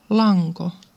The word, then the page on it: lanko: [ˈlɑŋko]; [ˈlɑŋkʷo̞]